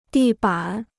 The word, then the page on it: 地板: dì bǎn